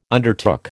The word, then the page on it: undertook: American English